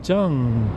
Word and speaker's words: Tsang: As opposed to myself making a fuss about how my name should be pronounced, because there's the romanization, T-S-A-N-G, but that's not how you say it. I mean, it's not English, so it's Zheng. And yeah, I'm not going to say my own name wrong.